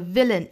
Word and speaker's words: villain: villain 一定要注意，不要读成了 villain 哈，不是那个 w 的发音，而是一定要上齿去摩擦下嘴唇的一个 v，会感觉到下嘴唇痒痒的感觉哈。